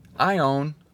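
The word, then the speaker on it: Aion (αἰών): Aion, sounds just like it, doesn't it?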